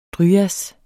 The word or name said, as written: dryas